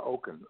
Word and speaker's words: Okun: It's Barbara Okun, O-K-U-N, Barbara Okun.